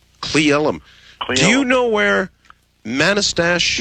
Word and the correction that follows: Manastash: Yeah, it's pronounced Manastash.